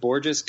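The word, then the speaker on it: Bourjos: They signed Peter Borjo? How do you say his name? – I've always pronounced it like Borges because I think people called him Gorgeous Borges for a while.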